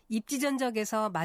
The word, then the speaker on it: 입지전적: 앞서 여러 번 들으셨듯이 입지전적에서 마지막 음절을 적으로 발음하지 않고 적으로 발음했습니다. 그것은 적이라는 전미사 앞에 니은 받침을 쓰는 표현인 입지전이 왔기 때문에 된 소리로 발음하지 않는 것입니다.